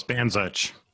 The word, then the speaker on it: Bandzuch: Close, Bandzuch.